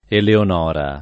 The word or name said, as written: Eleonora